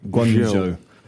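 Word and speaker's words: Guanyu Zhou: Got to say, Guan Yu Zhou, I can't say his name. – Guan Yu Zhou.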